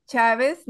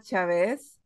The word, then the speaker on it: Chavez: Well, my name is Liz Chavez, not Chavez. Like many English-speaking people say, I appreciate that you pronounce it correctly.